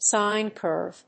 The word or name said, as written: sine curve